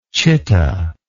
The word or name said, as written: citta